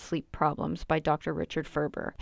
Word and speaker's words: Ferber: I was on my way to Chapters and I just kept saying rhymes with Gerber, Ferber, because I didn't want to forget who the author was that I was looking for when I got there. I wasn't really sure how many other books maybe were called How to Solve Child's Sleep Problems, so I wanted to make sure I got the right one. And it was really easy, because Gerber rhymes with Ferber, and Gerber is such a popular baby food company that it really kind of just tied together.